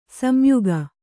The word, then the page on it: ಸಂಯೋಗ: samyōga